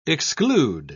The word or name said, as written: exclude